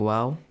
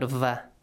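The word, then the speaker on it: واو: So, first we have waw. – Waw, pronounce it wa, not va.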